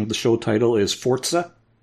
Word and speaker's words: Forza: So, it's pronounced Forza because it's Italian.